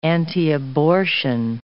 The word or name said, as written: antiabortion